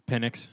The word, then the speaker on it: Penix: And then Michael Penix. Am I saying it right? Penix. – Yeah. Or just say Penix because it's funnier.